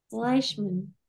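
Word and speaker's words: Fleishman: I need to finish Flashman. I'm into it. – Fleischman. You're saying Flashman. – How do you say it? – Fleisch, like fly swatter. Fleischman.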